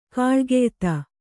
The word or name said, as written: ಗುಳಿಗೆಯೆತ್ತು